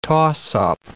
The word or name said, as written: toss-up